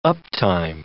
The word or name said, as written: uptime